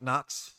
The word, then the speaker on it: Knoxxx: My name is Mike Knox, spelled with three X's, because, damn it, I just say the things you just refuse to say, so I do that.